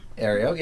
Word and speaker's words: Aereo: Am I saying that right? – Aereo, yes, correct.